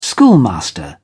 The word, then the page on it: schoolmaster: British English